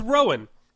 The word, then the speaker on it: Rowan: I can never say her name right, especially with the cold. It's Rowan.